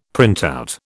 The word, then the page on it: printout: British English